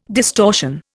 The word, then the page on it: distortion: Indian